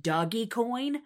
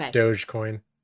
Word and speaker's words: Dogecoin: it's not pronounced doggy coin. And I'm like, well, duh. But then he didn't actually pronounce it. So Dogecoin? – I'm pretty sure it's Dogecoin.